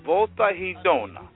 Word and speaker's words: Volta Redonda: Now, am I saying this right? Volta Redonda. Sing. Volta. Volta Redonda. Fala, sing her now. Volta Redonda is where she is. It's a little city outside of, I'm getting my some Portuguese lesson. Okay, I said it right.